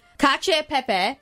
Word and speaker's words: cacio e pepe: Plum, we've made cacio e pepe. Did I say that right? – You said it perfectly.